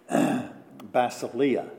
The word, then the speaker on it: basileia: Basileia is how it's pronounced.